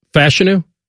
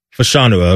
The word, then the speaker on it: Fashanu: Or wait a minute. It's Fashanu? – Fashanu, I believe, yeah, is what we're going with.